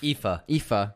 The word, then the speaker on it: Aoife: AF. I'm sorry, I always say that Irish name wrong. How do you pronounce it? – Which one? – Aoife. – Aoife. – Jeez, I'm sorry, Aoife.